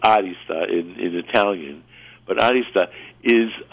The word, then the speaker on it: arista: I put the accent on the A, like arista, because it's actually a Greek word,